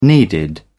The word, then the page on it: needed: British English